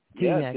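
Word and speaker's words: Teaneck: T-neck.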